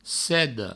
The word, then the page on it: seda: sedo